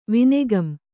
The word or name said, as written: ವಿನೆಗಂ